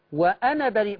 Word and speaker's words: وأنا: وأنا بريء. بص كده، كلمة أنا الألف مرسوم عليها إيه؟ – عليها دايرة بس بالطول. – أيوة، الدايرة اللي عليها سكون دي معناها أن الألف لا تنطق وصلا.